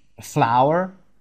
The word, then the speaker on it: flour: پس میگید A cake is made from flour. F-L-O-U-R که شبیه گل تلفظ میشه. Flour. ولی W نداره.